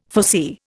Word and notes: foresee: Indian

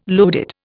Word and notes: loaded: Indian

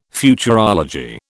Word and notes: Futurology: British English

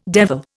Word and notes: devil: Indian

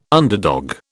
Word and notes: underdog: British English

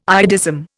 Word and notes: iodism: Indian

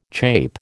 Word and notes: chape: American English

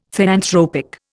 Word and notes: theanthropic: Indian

